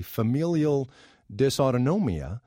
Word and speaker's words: familial dysautonomia: A 16, 17-year-old girl, her name is Flicka, with a horrible degenerative disease called, if I'm pronouncing it correctly, familial dysautonomia.